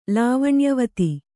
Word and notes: ಲಾವಣ್ಯವತಿ: Kannada

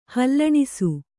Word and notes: ಹಾಲುಣಿಸು: hāluṇisu

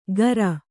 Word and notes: ಗರ: Kannada

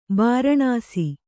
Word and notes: ಬೂರ್ನೀಸು: Kannada

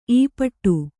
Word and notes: ಈಪಾಟಿ: Kannada

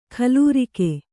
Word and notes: ಖಲೂರಿಕೆ: kannada

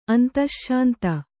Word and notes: ಅಂತಶ್ಶಾಂತ: antaśśanta; kannada

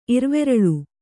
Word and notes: ಇರ್ವೆರಳು: Kannada

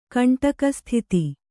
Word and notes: ಕಂಟಕಸ್ಥಿತಿ: kannada

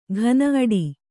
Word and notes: ಘನ ಅಡಿ: ghana aḍi

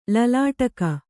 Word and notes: ಲಲಾಟಕ: lalāṭaka

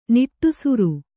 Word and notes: ನಿಟ್ಟುಸಿರು: Kannada

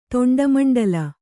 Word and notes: ತೊಂಡಮಂಡಲ: kannada

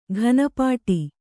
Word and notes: ಘನಪಾಠ: ghana pāṭha

